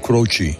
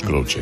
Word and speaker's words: Croce: Tienes la mejor la tendencia de, uh-huh, de pronunciarlo Crouch. Uh-huh. No es Crouch, es Crouchy.